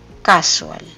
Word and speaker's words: casual: Y lo pronunciamos distinto. El acento va en la primera sílaba. Casual.